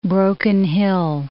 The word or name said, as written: Broken Hill